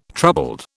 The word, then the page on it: troubled: British English